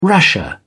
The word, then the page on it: rasher: British English